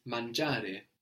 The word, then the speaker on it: mangiare: This is spelled M-A-N-G-I-A-R-E. And the I is not really pronounced here, is it? Mangiare. It's actually just giving us a soft G as opposed to a hard G. We've seen this before. Giorno, the word for day, which is spelled G-I-O-R-N-O.